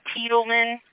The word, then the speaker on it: Tiedemann: But it's Tiedemann. Looks like it's spelled T-I-E, and then Demon.